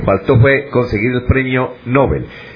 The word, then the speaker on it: Nobel: Se dice Nobel porque el premio, el señor Alfredo Nobel, en sueco se pronuncia Nobel, no Nobel, y los apellidos o los nombres pasan al español tal como suenan en su idioma original. Entonces, si en sueco es Nobel, pues en español también es lo mismo, Nobel.